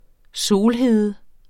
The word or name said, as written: solhede